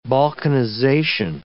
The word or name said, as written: balkanization